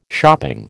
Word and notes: shopping: American English

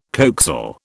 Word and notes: coaxial: British English

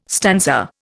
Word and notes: stanza: Indian

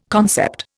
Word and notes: concept: Indian

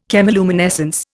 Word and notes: chemiluminescence: Indian

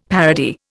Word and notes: parody: Indian

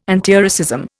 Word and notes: antiracism: Indian

